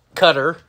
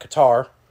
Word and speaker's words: Qatar: In Qatar, as I am now told that it is pronounced. Not Qatar.